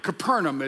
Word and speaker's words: Capernaum: We'd made our way with a busload of church members up to Capernaum, Capernaum as they pronounce it.